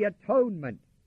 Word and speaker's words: atonement: And not only so, but we also joy in God through our Lord Jesus Christ, by whom we have now received the atonement. The word atonement is reconciliation. If you would pronounce it at-one-ment, A-T-O-N-E-M-E-N-T, at-one-ment with God, we have been reconciled.